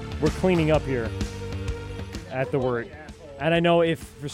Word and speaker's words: wort: It is the wort, not warts, not forts, not short, the wort.